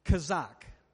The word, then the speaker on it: hazaq: Either one comes from the Hebrew word, which is H-A-Z-A-Q. Hazak. And so that's how I would say it in English. It's actually said, the way the Hebrews pronounce it is kazak, which made me think about when I was a kid.